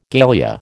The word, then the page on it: galea: American English